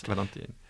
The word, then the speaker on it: Valentine: Yes, Valentine. – Valentine, if we use the French pronunciation, even though she was American.